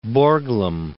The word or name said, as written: Borglum